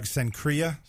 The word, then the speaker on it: Cenchreae: How do you say that? Cenchreae? – Yeah, I got it from Doug. Cenchreae.